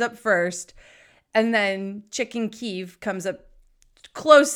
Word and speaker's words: Kyiv: Well, first and foremost, if you've been watching the news, you know that the capital city of Ukraine is not pronounced Kiev, it's pronounced Kyiv.